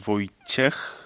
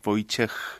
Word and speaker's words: Wojciech: Je m'appelle euh, Wojciech. C'est un peu difficile à prononcer pour les Français parce qu'en France, euh, H, on prononce pas. En Pologne, on prononce, comme dans les autres pays, comme H, Wojciech.